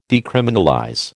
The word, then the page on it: decriminalize: American English